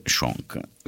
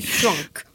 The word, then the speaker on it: Swank: Ma parliamo un po' di Laura, insomma, abbiamo parlato di Hilary Swank, Swank, così si pronuncia, perché ridi? Swank. Giusto? Ok. – Shonk. – Va bene, Shonk.